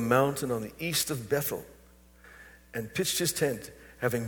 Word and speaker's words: Bethel: Our name Bethel in Spanish is Bethel. H is silent in Spanish.